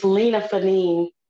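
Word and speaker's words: Lina Fanene: It's Lena Fanine.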